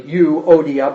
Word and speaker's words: Euodia: I urge Euodia. It's actually supposed to be pronounced separately, Euodia, but I never do, so don't worry about that.